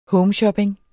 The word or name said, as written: homeshopping